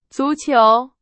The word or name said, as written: 足球